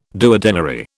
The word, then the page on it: duodenary: British English